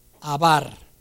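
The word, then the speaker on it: abar (עבר): Entonces, hebreo viene de una raíz hebrea del idioma hebreo, que significa atravesar, cruzar, pasar, y se pronuncia abar. Así se pronuncia.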